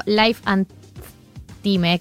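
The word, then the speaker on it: Life and Thyme: Y si te gusta leer un poquito más y te cabe la onda, el newsletter del sitio Life and Time, que se escribe T-H-Y-M-E, que tiene novedades internacionales del mundo gastronómico.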